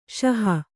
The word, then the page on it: ಸಾಹು: Kannada